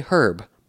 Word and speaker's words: herb: But kind of the core of it is that the word herb in English is a loan word from French. And the French never pronounce the H at the beginning of a word. Other examples are honest or air. And so herb is the same kind of loan word. And so Americans don't pronounce the H at the beginning of that word. But the British do. They say herb.